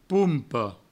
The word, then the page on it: pompa: poumpo